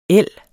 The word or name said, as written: el